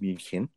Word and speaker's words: München: Paulaner München, which hopefully some of our German audience appreciate that I didn't say Munich, properly pronounced München.